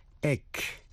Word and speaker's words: ache: Bây giờ ta học thêm 14 chữ, trong đó CH phát âm là kh. Đó là ache, A-C-H-E. Ache là đau nhức. Stomach ache, đau bụng. Schedule, thời biểu. Scheme, S-C-H. E-M-E là kế hoạch hay là mưu mô.